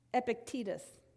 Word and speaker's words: Epictetus: There's a first century philosopher, and my husband was laughing at me yesterday because I had to actually get on Google to figure out how to say his name. Epictetus. Yeah, see, it's Google.